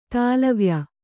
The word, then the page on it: ದಳವಾಯ್: daḷavāy